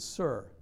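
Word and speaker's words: Sur (צור): It's the Hebrew word is Sur, like Big Sur, where Paul came from, Big Sur.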